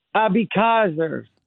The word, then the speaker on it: Abeckaser: Abikazer.